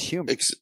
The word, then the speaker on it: humor: When did New Yorkers start pronouncing humor with a Y? Like, when did that happen? It's an H on the front of that word. It's humor.